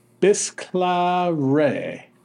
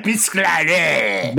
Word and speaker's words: Bisclavret: Yeah, biscuare. – Biscuare. – Say it like you mean it, you United States. – Say it like States. – You're chomping on a croissant.